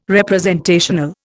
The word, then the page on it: representational: Indian